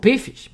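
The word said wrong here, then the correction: Phish: Pish, que yo nunca he podido pronunciarlo bien. Fish. Sí, Fish. ¿Con PH? Sí, el de PH, sí, Fish.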